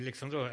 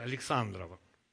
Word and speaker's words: Александрова: Только ударение я сделаю так. Не Александрова, а Александрова.